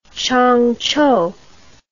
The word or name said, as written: Changchow